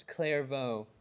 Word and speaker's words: Clairvaux: A different Frenchman, and his name was Bernard of Clairvaux, which might be one of my most favorite words, Clairvaux. Yes, everybody loves a silent X.